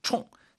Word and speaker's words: 冲: 총이 이제 이게 일성으로 발음할 때 있고 사성 발음할 때인데 사성 총, 셉니다.